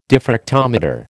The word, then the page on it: diffractometer: American English